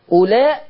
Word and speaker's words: أولاء: هي الواو دي تنطق؟ – لا. – هو دي بقى، انتبهوا كلكم وكل اللي بيشوفوني دلوقتي، أولاء الواو لا تنطق.